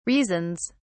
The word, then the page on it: reasons: British English